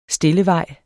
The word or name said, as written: stillevej